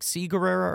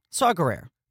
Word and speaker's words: Saw Gerrera: Don't they say, like, Sea Gerrera or something? – No, Saw. – I kept on thinking the way they pronounced it was weird. – They just say Saw.